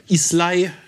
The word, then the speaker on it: Islay: Schreib Islay, sprich Isla. – Und Islay.